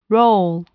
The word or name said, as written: role